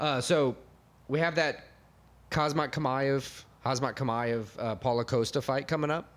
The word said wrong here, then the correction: Khamzat Chimaev: Khamzat Chimaev. – I said those two back, the first syllable backwards on both of them. So the backup for that fight is Kamaru Usman.